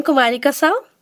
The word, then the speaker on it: Kumarika Sau: I am Kumarika Sao.